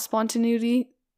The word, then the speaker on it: spontaneity: I like a certain amount of spontaneity. I can't believe I just pronounced that word right on the first try.